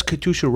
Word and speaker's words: Katyusha: Is that the right way to say it? – Yeah, I think Katyusha? – It is.